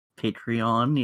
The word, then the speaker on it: Patreon: Patreon, yes.